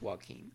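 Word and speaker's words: Joachim: J-O-A-C-H-I-M. I'm going with Joaquin.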